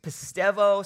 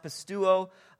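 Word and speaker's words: pistevo (πιστεύω): The word in Greek is pistevo, sometimes pronounced pistuo.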